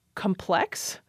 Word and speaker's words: complex: And she noted that she rarely hears the adjective complex, stressing the second syllable the way I just did. She says the adjective is now often pronounced stressing the first syllable.